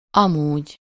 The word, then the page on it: otherwise: American English; [ˈʌð.ɚ.ˌwaɪz]